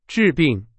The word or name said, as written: 治